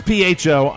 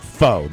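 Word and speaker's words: phở: It's pho. I'm pronouncing it pho. That's the way it's going to go.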